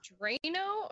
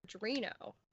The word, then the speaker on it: Adreno: But, are you saying Drano or Adreno? – Adreno. At least I think that's how it's pronounced. – Adreno. It's like adrenaline, but...